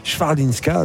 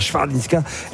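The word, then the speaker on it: Chwalińska: Swarlinska. Alors voilà comment on doit prononcer le nom de cette polonaise, hein, Swarlinska.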